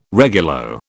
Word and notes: regulo: British English

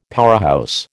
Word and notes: powerhouse: American English

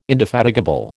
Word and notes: indefatigable: American English

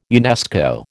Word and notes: UNESCO: American English

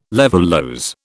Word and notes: levulose: British English

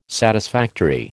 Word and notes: satisfactory: American English

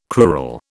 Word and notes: crural: British English

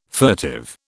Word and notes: furtive: British English

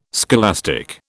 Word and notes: scholastic: British English

